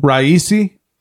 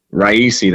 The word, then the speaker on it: Raisi: So, Raisi, how do you say his name again? – Raisi? – Raisi, thank you.